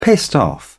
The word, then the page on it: pissed off: British English